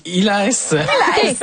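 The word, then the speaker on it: Iles: C'est de Greg Illes. – Oui. – On dit comme ça. – Oui, ben, je sais pas. Moi, je suis pas la meilleure en prononciation. On va demander. – Ben, je sais pas. Illes. – Illes, voilà. Donc, c'est chez Actes Sud.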